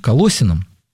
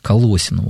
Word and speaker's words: Колоссянам: Вот, есть такое послание Колоссянам. Кто-то говорит Колоссянам по стариночке так, ну, говорят, Колоссы был такой город, говорят, что вот более правильно говорить Колоссянам, вот так ударение ставить.